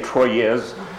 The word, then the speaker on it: Troyes: And he said, un, deux, trois. Trois, three. That's how you pronounce T-R-O-Y-E-S.